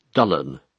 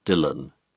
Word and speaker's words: Dylan: The BBC announcer uses the traditional Welsh pronunciation of the name Dullan, not Dylan. But Thomas thought that Dylan suited him better.